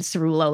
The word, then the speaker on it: Cerullo: And in that case, I've done Cerullo like Cerulean Blue.